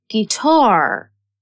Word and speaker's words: guitar: U is a silent letter in guitar.